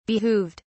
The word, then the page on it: behooved: British English